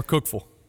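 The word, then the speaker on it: Cookeville: Cookville or Cookville. You just run it all together.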